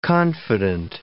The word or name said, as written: confident